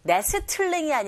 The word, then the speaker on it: nestling: Nestling이 아니라 nestling. Nestling. T 발음 역시 안 들리죠.